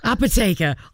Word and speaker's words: apothecary: And the apothecary. Do I say that right? – Apotheker. – I knew that wasn't right.